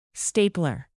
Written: stapler

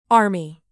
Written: army caterpillar

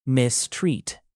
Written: mistreat

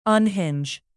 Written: unhinge

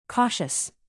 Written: cautious

